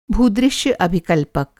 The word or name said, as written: भूदृश्य अभिकल्पक